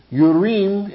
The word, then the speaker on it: Urim: Urim in Hebrew and Thummim is literally how it's pronounced.